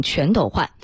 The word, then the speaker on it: 全斗焕: 更正一下刚刚的一个读音错误啊，前总统全斗焕。